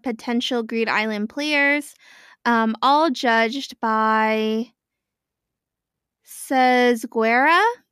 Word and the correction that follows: Tsezguerra: it is t- t- like T S. – Yeah, yeah. – Tezguera. – Tetscara. – No, no, no. There's no. Uh. It, it, it really doesn't matter. I'm just clarifying for the readers right now, uh, for the listeners, and then we can all pronounce it however we want. But it's Tetscara.